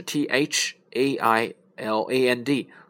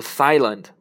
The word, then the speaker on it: Thailand: 类似的还有一个是泰国 Thailand，OK，Thailand，但是它的拼写呢是 T H A I L A N D，所以我们不能读 Thailand，必须要读 Thailand，OK，泰国。